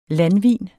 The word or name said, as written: landvin